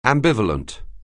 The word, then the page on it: ambivalent: anglais